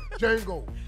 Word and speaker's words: Django: You know, kind of like the movie Django. – With Jamie Foxx? – The D is silent. – The D is silent. – Yeah, Django.